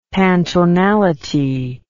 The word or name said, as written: pantonality